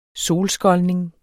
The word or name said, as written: solskoldning